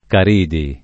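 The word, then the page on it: Caridi: italiano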